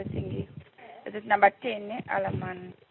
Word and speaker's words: Alleman: Is it Alleman or Alleman? – Alleman. – Okay. Spell that for me. Go. And we'll get the upgrade. – A-double-L. – A-double-L. – E-M. – E-N. – A-double-N. – A-double-N. – So Alleman.